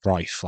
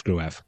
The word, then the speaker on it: Gruev: Well, to be honest, I was doing some looking after it because after the recent games where Bryn Law has been pronouncing it Greif, like Trife, but with a G, I was looking into some old footage of Greif when he was in the Bundesliga, and the Bundesliga commentators pronounced it as Gruev.